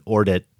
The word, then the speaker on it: Ordet: I'm sure just like in Ordet, the D is silent.